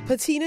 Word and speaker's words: patina: Patina is a noun. It is spelled P-A-T-I-N-A. P-A-T-I-N-A. Pronounced patina.